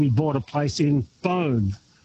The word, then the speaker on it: Beaune: Well, Beaune, you said it very well. Beaune is one of the most amazing French cities in Burgundy.